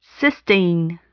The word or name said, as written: cysteine